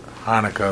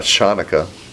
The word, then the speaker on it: Hanukkah: Hanukkah is good. – No, it's not Shanukkah. – No, it's Hanukkah. Hanukkah, yeah.